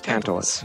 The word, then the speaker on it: Tantalus: Tantalus or Tantalus? – Tantalus.